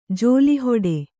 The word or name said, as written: ಝೋಲಿಹೊಡೆ